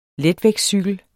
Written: letvægtscykel

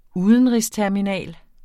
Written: udenrigsterminal

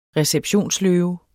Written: receptionsløve

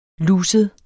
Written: luset

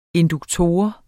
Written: induktor

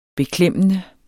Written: beklemmende

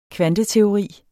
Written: kvanteteori

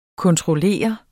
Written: kontroller

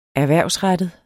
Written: erhvervsrettet

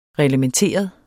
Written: reglementeret

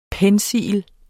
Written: pencil

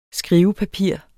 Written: skrivepapir

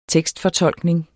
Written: TEKSTFORTOLKNING

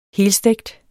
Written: helstegt

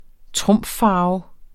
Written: trumffarve

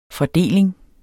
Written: fordeling

